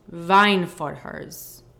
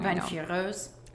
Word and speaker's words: Weinführer: One of the main things they talk about also in the book is these Weinführers. I'm saying that wrong. – Weinführers. – There you go.